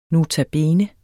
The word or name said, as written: notabene